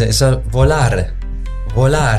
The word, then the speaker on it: Volare: Wie zum Beispiel, Volare. So ist es ja richtig.